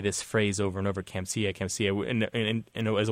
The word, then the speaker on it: Kamsia: transliterated into Roman characters as Kamsia, uh, sia," K A M S I A, um, with a long A.